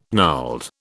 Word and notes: gnarled: British English

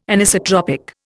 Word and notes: Anisotropic: Indian

